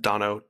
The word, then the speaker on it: Daunno: And this one, it's since it's a live performance, it's Damon, I think his last name is pronounced Dano, D-A-U-N-N-O.